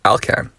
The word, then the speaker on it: Alcan: So on to the pronunciation of A-L-K-A-N. It is in fact Alcan.